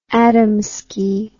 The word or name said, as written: Adamski